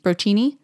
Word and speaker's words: Brocchini: And it's Detective Brockney, B-R-O-C-C-H-I-N-I, Brockney? Brocchini? – Oh, I knew how to say it. I can't remember. – Okay, we're going to go with Detective Brocchini.